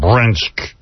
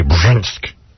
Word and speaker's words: Brzenk: His name's John, I can't even say his name, Brzenk. John B-R-Z-E-N-K, Brzenk. Brzenk. Brzenk. – Yeah, John Brzenk. – Yeah, baby. – E-N-K? – B-R-Z-E-N-K, Brzenk. – B-R-Z? – Yeah, Brzenk. – That's ridiculous. – John Brzenk.